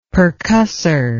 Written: percussor

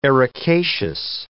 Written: ericaceous

